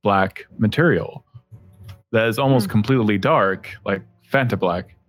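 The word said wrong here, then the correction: Vantablack: or Vanta Black. I always say it wrong.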